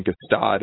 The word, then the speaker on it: Gstaad: They finished fifth in Gstaad in July.